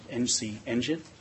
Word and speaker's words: NCNGN: Just to serve as a refresher, the North Carolina Next Generation Network, or NCNGN, pronounced NC Engine,